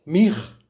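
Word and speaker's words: Mij (מיכ): Deberás meditar el santo nombre que se escribe Mem Yud Kaf, que se pronuncia Mij,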